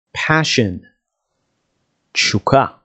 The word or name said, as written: passion